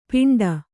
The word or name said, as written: ಪಿಂಡ